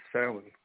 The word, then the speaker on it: Samhain: It's Samhain, but the phonetics of Irish are completely different from those of English. So the way you say it is Samhain. – In Greek, it's the same thing. Like Zeus is actually pronounced Zephs, like Z-E-F-S.